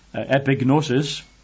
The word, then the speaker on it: epignosis: Epignosis, which if you spell that in English lettering, is E-P-I, epi. Gnosis, G-N-O-S-I-S, epignosis.